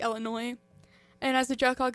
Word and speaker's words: Illinois: I pronounced the S. Sorry, guys. – You went through Illinois. – Illinois.